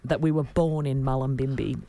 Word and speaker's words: Mullumbimby: I think it's Mullumbimby is how it's pronounced. – Mullumbimby. – Both my bestie from primary school,